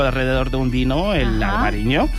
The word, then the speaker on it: Albariño: Claro, ya están en plena Festa do Albariño.